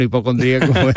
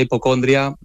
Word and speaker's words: hipocondría: Para mí oficialmente es hipocondría, con acento en la I. – Exacto. – Pero me he encontrado mucha gente que lo llama hipocondria y bueno, nos entendemos.